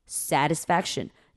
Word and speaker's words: satisfaction: 好，再来 satisfaction，这边呢，你不要把 t 念出来，你念成 satisfaction， satis，仔细听哦，是的的音 satisfaction。